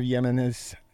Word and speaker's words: Yemenis: So the attack came as a delegation of Yemenis. That's the way it's pronounced, appeared before a congressional panel to testify on the impact of drone strikes.